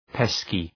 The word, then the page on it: pesky: ‘peskı